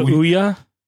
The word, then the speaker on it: Ouya: It's called Ouya. – Yeah. Because it's pronounced like Booya minus the B. It's O-U-Y-A.